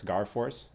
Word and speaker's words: Garforce: It's my last name, Garforce. Like in Garfield and US Air Force, Garforce. So it's not how you spell it, Garforce.